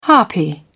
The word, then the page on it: harpy: British English